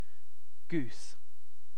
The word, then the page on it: goose: British English